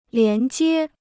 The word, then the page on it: 连接: lián jiē